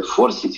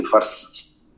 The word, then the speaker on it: форсить: Значит, форсить с этим ударением – это действительно модничать, э, наряжаться, хвастаться своей одеждой или каким-то умением.